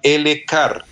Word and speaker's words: EleCare: Es una leche que se llama L-Car. Se, se escribe E-L-E-C-A-R-E.